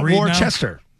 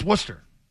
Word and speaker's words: Worcester: Like Worcester's Worcester. It's not Worcester. It's Worcester.